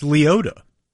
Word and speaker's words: Liotta: And that his last name is pronounced Liotta.